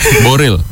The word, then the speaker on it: Boreal: 0,74 triliunnya berada di wilayah Boreal. Boreal suka-suka ya. – Boril. – Oh, bacanya Boril.